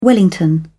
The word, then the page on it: wellington: British English